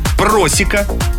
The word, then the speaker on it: просека: Как нужно произносить слова. Итак, просека, пурпур, бутики, пуловер и манты, когда будете заказывать, именно так произносите.